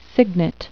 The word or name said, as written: cygnet